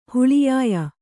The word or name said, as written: ಹುಳಿಯಾಯ